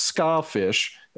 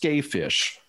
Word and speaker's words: Skafish: It looks like it's ska fish, and it's not a ska band, but it's pronounced Skayfish.